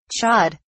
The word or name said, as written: chod